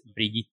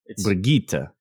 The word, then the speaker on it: Brigitte: If you listen to the way the voice actor, that's the correct pronunciation. So it's Brigitte. – Brigitte.